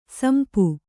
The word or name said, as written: ಸಿಂಪಿ